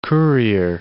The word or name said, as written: courier